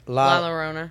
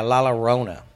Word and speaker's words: La Llorona: La Llorona. – Yeah, La Llorona.